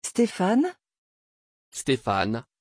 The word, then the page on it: Stefan: French